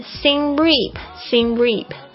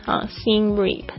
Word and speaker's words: Siem Reap: Number 7, Siem Reap, 是先例当然也有人会把这个 Siem 念成 Siem Reap， Siem Reap 都可以。